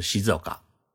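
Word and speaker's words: 静岡: 呃，安静的静，山岗的岗，一个县的县，日语念作是西泽冈。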